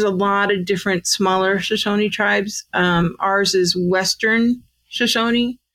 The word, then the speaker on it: Shoshone: So you guys are part of the Shoshones, correct? – Shoshone. – Shoshone. That's how you say it.